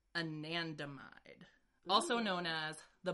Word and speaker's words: anandamide: Anandamide. Anandamide.